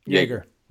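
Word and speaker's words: Joerger: No, so yes, now there's talk of firing, of, you know, there's tension between the front office and Yerger. Yerger? – Yeager. Yeager. Like a Yeager bomb.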